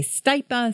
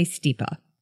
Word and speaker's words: stipa: And by the way, there are so many ways to pronounce stipa. Some people say stipa, some people say stipa, I say stipa.